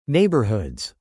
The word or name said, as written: neighborhoods